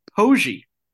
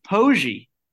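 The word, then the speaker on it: Poggi: His name is actually pronounced Pogey. Did you guys know this? – No. – It's Biff Pogey, and I learned this because College Game Day was talking about Biff Pogey.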